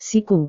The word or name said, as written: siku